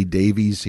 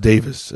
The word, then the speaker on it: Davies: I know we say Davies here in the United States, but it's actually pronounced Davis in the UK. It's spelled I-E-S.